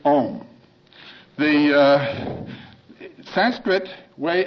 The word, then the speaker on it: Om: So we have this syllable, A-U-M. When you pronounce Om, it starts in the back of the mouth, ah, and then the sound mass fills the mouth, ooh, and then it closes at the lips, mm.